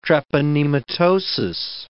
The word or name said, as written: treponematosis